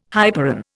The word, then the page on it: hyperon: Indian